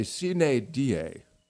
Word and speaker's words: sine die: I believe, Greg, the Latin scholars would say sine die. Having taken Latin in high school, I believe I'm correct on that.